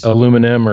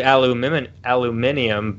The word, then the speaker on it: aluminium: Aluminum or aluminium? – It's actually aluminium, but us Americans, you know, we screw everything up. If you actually look at the way that it's spelled aluminium, but we don't pronounce it that way.